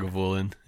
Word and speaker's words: Lagavulin: Is it Lagavulin? Is that how you say it? – Lagavulin, yeah.